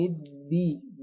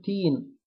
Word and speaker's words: الدين: مالك يوم الدين، يوم الدين دي، نجيبها دال ما نجيبهاش تاء، محدش يقول تين، أو محدش يجيبها دال كده شم شوية جيم، يعني الناس تقول إيه؟ مالك يوم الجين، لا، مالك يوم الدين دي، مالك يوم الدين.